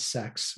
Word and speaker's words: CEX: And yes, they do pronounce it as sex when you hear about it on their advertisements.